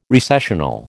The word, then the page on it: recessional: American English